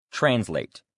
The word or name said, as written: translate